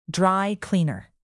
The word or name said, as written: dry-cleaner